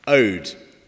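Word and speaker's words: ode (עוד): And there's a word in Hebrew that's pronounced ode, which means again.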